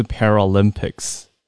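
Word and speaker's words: パラリンピック: 我问一下，帕奥的日文怎么读啊？因为英文是 Paralympics，所以才读 Para 嘛，翻成帕。 – 对对对。 – 那日文是 Para 吗？ – 呃，对，它叫做 Paralympic。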